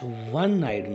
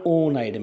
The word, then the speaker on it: one-eyed: He is dash one-eyed man, not O-eyed man.